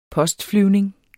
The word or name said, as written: postflyvning